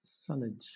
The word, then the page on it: sullage: British English